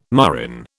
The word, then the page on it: murrain: British English